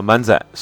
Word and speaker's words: 漫才: 漫才日语读作漫仔，是日本的一种喜剧形式，类似于中国的对口相声，起源于日本古代传统的表演形式万岁，之后在关西地区渐渐发展。